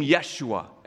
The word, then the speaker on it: Yeshua: Now, Jesus in the original Hebrew is the name Yeshua. Everyone say Yeshua.